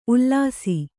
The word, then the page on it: ಉಲಿಸು: Kannada